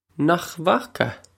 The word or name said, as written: Nach bhfaca